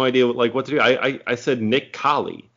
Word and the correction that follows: Kale: And they're just like, oh, that it's Kale.